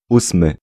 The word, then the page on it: eighth: /ˈeɪtθ/